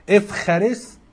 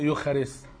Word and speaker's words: إفخارست: إفخارست ده النطق اليوناني الحديث ليوخارست، بيقول لك مش عارف ده صوت مزدوج مع حرف ساكن معين يتنطق اف، تبقى افخارست افخارستي، هي باليونان القديم تتنطق يوخارست.